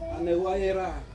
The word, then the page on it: anewayera: Ansus